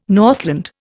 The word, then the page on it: Northland: Indian